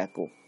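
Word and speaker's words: Akō: Por azares del destino, digamos, el dominio de Aku, o Aku, bueno, se pronuncia Aku, pero bueno, igual lo habéis visto por ahí como Aku, se le había concedido a su abuelo, Naganao, con unos ingresos de 50.000 koku.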